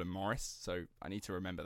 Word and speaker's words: Maurice: It's Morris, but it's spelt like it's Maurice, but the Australians say...